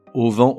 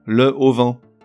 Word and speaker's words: auvent: En revanche, pour une raison que j'ignore, on lui invente un H aspiré, qui n'existe pas, mais qui nous fait dire le plus souvent le au vent.